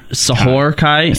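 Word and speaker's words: Sohr Khai: Sor Kai. Bum, bum, bum, bum. – Sahor. – Sahor Kai? – Sahor. – Is that how it's pronounced? – Yeah.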